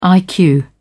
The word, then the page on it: iq: British English